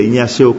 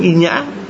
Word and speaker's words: Ignacio: Vì phát âm mà phiên âm tiếng Việt đọc là Thánh Inhã, còn nếu mà đọc nguyên tên của ngài gọi là Ignacio.